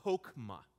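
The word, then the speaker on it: chokmah (חכמה): But wisdom in the Hebrew is the word chokmah. Chokmah. It's spelled H-O-K-M-A-H.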